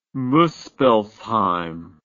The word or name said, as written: muspellsheim